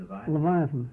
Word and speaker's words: Leviathan: I'm sorry, how do you pronounce it? – Leviathan.